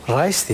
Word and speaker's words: Reis: Reis diye mi okunuyor? Hı hı.